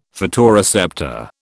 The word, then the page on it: photoreceptor: British English